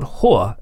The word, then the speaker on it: Hoa: Let me play you something new from a band called Hoa. It's pronounced that way, and it's spelled H-O-A, reason being they're from South Korea.